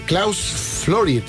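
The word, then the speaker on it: Klaus Flouride: 30 de mayo, 77 años de 1949, Klaus Florid. ¿Sí? ¿Lo dije bien? Sí.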